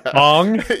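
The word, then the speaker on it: Aang: "Ong."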